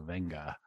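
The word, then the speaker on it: Wenger: But it is like, you know, I think it is the German pronunciation, which is that the W is pronounced more like a V. So Venga, Etienne Venga.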